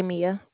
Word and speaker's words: Mia: Trey Mia. – You can spell that, please. – I'm sorry? – You can spell that? – M-I-A. Mia. It's fine. M-I-A.